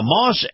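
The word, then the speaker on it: Maus: Now, Moss Acura, and Moss is spelled M-A-U-S, M-A-U-S, Moss Acura has got the beautiful, and I mean the beautiful, new, redesigned MDX on display right now, the 2021 MDX.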